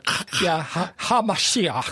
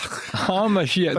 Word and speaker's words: HaMashiach: I don't know if I pronounce it, but it's Mashiach. – Yes. – Kind of with a HaMashiach. – HaMashiach. – But I'm not good on pronunciation of Hebrew, but it's close.